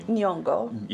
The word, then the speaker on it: Nyong'o: Nyong'o.